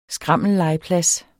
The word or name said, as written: skrammellegeplads